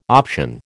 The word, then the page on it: option: American English